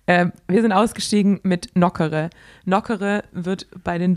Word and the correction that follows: Nokere: Nokere wird Nokere. ausgesprochen. – Nokere. – Nicht wie so ein Eis.